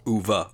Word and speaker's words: Uwe: Directed by our favorite director, Uwe Boll. – Uva, Uva, come on. Uva, even, sorry, Uva, Uva. Even Michelle, even, even Michelle Rodriguez said it right in that video clip you sent us.